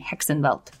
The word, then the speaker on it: Hexenweldt: It is spelled HEXENWELDT, Hexenwelt.